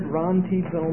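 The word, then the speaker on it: rantizo (ῥαντίζω): It's spelled R-A-N-T-I-Z-O, rantizo.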